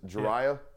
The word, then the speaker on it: Jireh: Jariah. – Jariah. – Jariah Edwards. J-I-R-E-H.